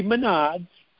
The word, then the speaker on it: Menards: Could be Menards. It's actually pronounced Menards, but Northeasterners say Menards.